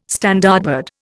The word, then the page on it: Standardbred: Indian